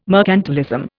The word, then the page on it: mercantilism: Indian